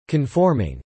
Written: conforming

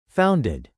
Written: founded

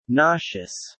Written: nauseous

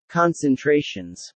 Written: concentrations

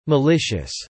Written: malicious

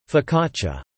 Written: focaccia

